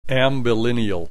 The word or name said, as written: ambilineal